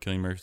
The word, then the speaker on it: Cillian: The movie is set place in the like mid-70s, and it stars Brie Larson, Cillian Murphy, which I've been pronouncing as Cillian Murphy my whole life, but Cillian Murphy, Armie Hammer, Charlton Copley.